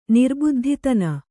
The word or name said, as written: ನಿರ್ಬುದ್ಧಿತನ